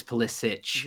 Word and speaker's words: Pulisic: But it was neat to see, like yesterday when Pulisic, everybody pronounces his name Pulisic. – Pulisic. – Technically, it's Pulisic. – Yeah, Pulisic. And, okay, but it's been Americanized.